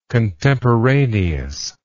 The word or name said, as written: contemporaneously